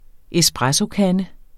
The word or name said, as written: espressokande